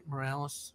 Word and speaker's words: Morales: Or am I saying that right, Morales? – Yeah. – Yeah, that's right.